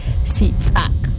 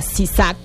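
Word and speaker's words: sitz'ak: El apóstrofe, que es importante para la pronunciación en el yucatán. Sin él no tiene sentido, sería sisac.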